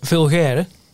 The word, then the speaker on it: Vulgaire: Vulgaire. ik ben geen, ben geen Tilburger, maar zo moet je het ongeveer uitspreken.